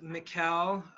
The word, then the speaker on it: Mikkel: My name is Mikkel, pronounced like Miguel with a K.